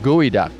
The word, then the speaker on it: Gweduck: It's spelled G-W-E-D-U-C-K. And I heard it's called Gooey Duck. – That sounds like Gooey Duck to me.